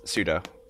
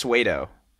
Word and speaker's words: pseudo: It's it is pseudo. I said it's suedo.